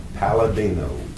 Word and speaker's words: Paladino: Raymond, R-A-Y-M-O-N-D. Last name, Palladino, P-A-L-A-D-I-N-O.